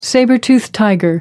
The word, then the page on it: sabre toothed tiger: American English